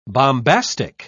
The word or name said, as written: bombastic